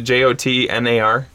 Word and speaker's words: Jotnar: I think it's soft J. – Jotnar. – The Jotar. – Jotar. – Is it? Yep. Was it Jotnar? – Yes.